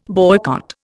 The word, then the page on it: boycott: Indian